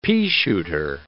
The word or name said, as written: peashooter